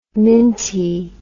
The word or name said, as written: mintie